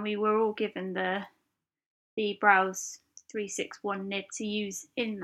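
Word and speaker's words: Brause: Another nib that I purchased was a Browse, I think it's pronounced Browse. It's B-R-A-U-S-E.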